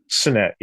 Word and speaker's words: Cenet: Cenet, is that how you pronounce it? Cenet, yes. Cenet.